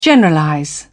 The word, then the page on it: generalize: British English